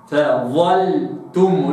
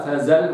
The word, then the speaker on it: فظلتم: فظلتم، مش فزل ولا كده، لابد أن يخرج اللسان وأفخمها، فأقول فظلتم.